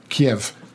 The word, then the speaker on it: Kyiv: K-Y-I-V and K-I-E-V. – Yeah. – Martin, you have to be congratulated on your pronunciation. Your Kyiv was perfect.